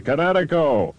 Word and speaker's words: Kinetico: Everybody knows that Kinetico is spelled K-I-N-E-T-I-C-O. – I thought the instructor said Connecticut. Oh, that means coast son, you should have remembered kinetic as in kinetic energy with an O on the end. Kinetico. – Kinetico. Spell it K-I-N-E-T-I-C-O.